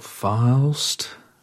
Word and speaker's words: Faust: How do you say that? Faust? ARP? – I think so. Faust.